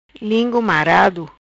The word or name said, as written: Lingu Marado